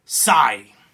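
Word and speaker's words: XI: Saga Frontier, and finally, and this is the one, this is the kicker, folks, this is the goal, Psy, X-I, it's pronounced Psy. Now, that's Japanese, Psy.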